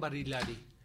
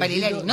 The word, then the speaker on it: Barilari: Adrián Barilari. Me quedé a ver si lo he dicho bien el apellido. – Barilari. No, sí, lo dijiste bien.